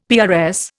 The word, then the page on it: PRS: Indian